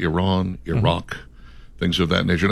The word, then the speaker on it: Iran: But yeah, the proper pronunciation is Iran.